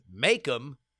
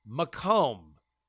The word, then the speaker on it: Macomb: And then it hit me what I had pronounced as Makem. I guess it was like Macon, Georgia for me, is indeed pronounced Macomb. Said much like Macomb, thanks to the inundation of the French in the early days of colonization.